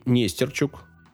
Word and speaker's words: Нестерчук: Также туда входили антрополог Нестерчук, именно так. Э, стоит ударение в Википедии, дальше я копать не стал, там нет ударений.